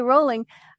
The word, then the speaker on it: Rowling: And I said last night, I was telling this story at dinner, and I said, R-O-W is pronounced Row. – Yeah, it is. – Why do we say Rowling? And it's that L, because it's like growling. Growl. – Exactly. Yeah. – So J.K. Rowling, she mentioned that she liked to collect funny names.